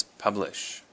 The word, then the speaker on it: publish: So our first word today is publish. Publish, and the emphasis is on the first syllable, the pub.